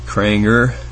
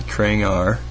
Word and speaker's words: Kranger: Earlier today, I declared that Kranger, E-R, it's pronounced like the E-R, Kranger, not Krangar, Krangar, Kranger, Kranger, damn it, Kranger 18, beat Gary Gnu, and we'll play Jim in the semifinals.